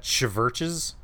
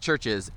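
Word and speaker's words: CHVRCHES: And it's like, so is that Chverches? – Yeah, exactly. No, it's pronounced Churches.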